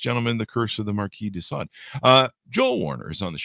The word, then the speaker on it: Marquis de Sade: It came out February 21st, 2023, The Curse of the Marquis de Sade. I believe I had that pronounced correctly. Yeah, there you go.